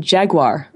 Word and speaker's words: Jaguar: Now, the next one we have is pronounced differently depending on which country you're in. So I'll say American, Jaguar. – And I'll say Jaguar. – I think Jaguar. In English it's pronounced in British it's Jaguar.